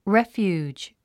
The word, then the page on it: refuge: réfjuːdʒ; レフュージ